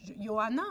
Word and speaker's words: Johanna: Jeanne, euh, en grec, son nom se prononce euh, Johanna, hein, c'est Johanna, Johanna.